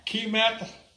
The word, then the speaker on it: Kemet: Kemet, that's how I pronounce it. K-E-M-E-T.